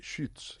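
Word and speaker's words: Schütz: No, pero además es que es Schutz. – Schutz. – Schutz. – Schutz. – Los labios como si estuvieran para pronunciar una U, pero que sean una I. Schutz.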